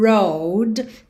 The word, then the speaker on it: railroad: Porque a gente tem dois R's enrolados. Railroad. railroad. Então, o começo aí das duas sílabas, né? O começo de rail e o começo de road tem o R enrolado.